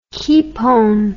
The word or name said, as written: Kepone